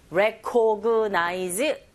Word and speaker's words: recognize: Recognize. 자, 이렇게 발음하시면 안 되고 강세를 주셔야 되죠. 정확한 위치에 re, 이 부분 첫 번째 있습니다.